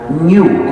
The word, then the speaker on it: GNU: En castellano es aceptable pronunciarlo GNU, como si comenzara por ñ, lo que es también el nombre del mismo animal.